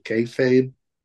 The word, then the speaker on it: kayfabe: This was when everything was still, I don't know how to pronounce it, kayfabe.